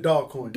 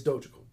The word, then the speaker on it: Dogecoin: Is that how it's pronounced? – Yeah. – Everybody's been calling it Dogcoin. – Dogcoin for the longest. – I thought it was Dogecoin. – Yeah, it's Dogecoin.